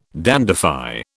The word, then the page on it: dandify: British English